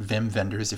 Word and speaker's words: Wim Wenders: That's two W's, Wim Wenders, but you pronounce it Wim Wenders if you're a pretentious movie person like me.